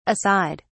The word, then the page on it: a side: British English